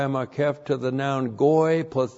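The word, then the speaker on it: goy (גּוֹי): And then we have goy. G, long O, Y, goy.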